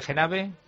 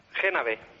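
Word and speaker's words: Génave: ¿Pronunció bien lo de Genave? – Genave. – Genave, perfecto. Me fallaba el acento.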